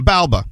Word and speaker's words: Balba: No, it's under my name, Balba. Okay, Balba. – Can you spell that for me, please? – B-A-L-B-A. Balba. – B-A-L-B-A.